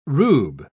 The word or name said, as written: rube